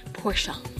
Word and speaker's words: Porsche: Porsche.